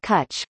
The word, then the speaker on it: cwtch: Lewis said CWTCH, a Welsh word for hug pronounced something like cutch, was inspired by market research with sex workers and queer communities that experienced censorship and malicious surveillance.